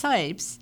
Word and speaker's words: Scipes: Kim's last name is pronounced Sipes, but it is spelled S C I P E S in order to reference him and the work he's done in his life.